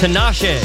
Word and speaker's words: Tinashe: Actually, it's pronounced Tinashe.